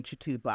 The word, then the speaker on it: Chitupa: And I then went to boarding school in the UK, and I reverted back to my name Chitupa.